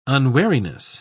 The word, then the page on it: unwariness: British English